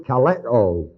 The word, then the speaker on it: kaleo: The root of this word called here in Romans 1, the root is the word kaleo, K-A-L-E-O, long O, according to the Greek text.